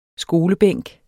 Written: skolebænk

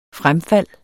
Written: fremfald

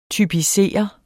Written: typisere

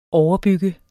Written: overbygge

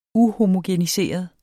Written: uhomogeniseret

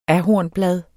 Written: ahornblad

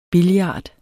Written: billard

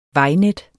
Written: vejnet

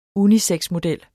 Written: unisexmodel

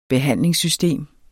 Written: BEHANDLINGSSYSTEMET

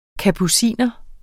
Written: kapuciner

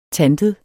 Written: tantet

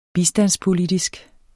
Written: bistandspolitisk

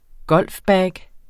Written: golfbag

